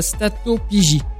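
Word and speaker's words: stéatopygie: Il y a quelques minutes, je vous ai demandé si vous saviez ce qu'était la statopigie. Donc j'avais même eu du mal à le prononcer. Là, je le reprononce bien.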